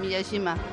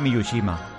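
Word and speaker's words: Miyajima: Lo has dicho bien, Miyashima. – Es que yo tenía la manía de decir Miyushima.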